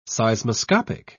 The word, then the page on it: seismoscopic: British English